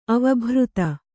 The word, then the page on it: ಅವಭೃಥ: kannada